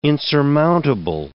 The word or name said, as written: insurmountable